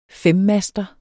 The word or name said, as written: femmaster